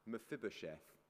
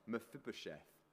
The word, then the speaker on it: Mephibosheth: His name is an interesting one to pronounce, Mephibosheth. Make sure you've got your teeth well glued in before you try it. Mephibosheth. And David seeks out Mephibosheth, and he invites him to join his royal table.